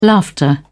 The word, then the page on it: laughter: British English